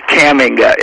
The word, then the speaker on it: Kamenga: It's Kamenga, emphasis on the first syllable, but that's no problem.